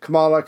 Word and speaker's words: Kamala: If the Dies Committee was around now, you know damn sure that Bernie Sanders, um, AOC, other people like that, Camila Halleck, uh, however you say her name, Harris, Kamala, Kamala Harris.